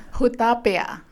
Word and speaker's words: Hutapea: Hutapea.